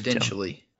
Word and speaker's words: exponentially: I've been saying expo den chili my entire fucking life. Exponentially. – I thought there was a den, not a nen.